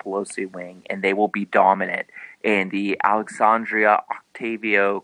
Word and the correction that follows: Ocasio: Cortez, I can't pronounce her. – Ocasio. – Name. Ocasio. Thank you very much.